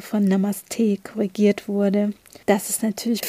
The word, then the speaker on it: Namaste: Es ist kein, kein T oder kein Stay drin, sondern es ist einfach Namaste. Die Betonung liegt auch eher auf der zweiten Silbe.